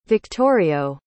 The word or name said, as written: victorio